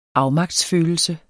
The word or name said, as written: afmagtsfølelse